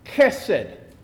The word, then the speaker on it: chesed: The word is chesed. Okay? Chesed. Kind of like K-E with a little slur on the S. Say that part, would you? And then there's an unstressed syllable after that, ed, chesed.